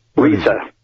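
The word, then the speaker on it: Riese: RISA. Anytime you get I and E together, you forget the first letter and pronounce the second one. So this is IE, so it's RISA.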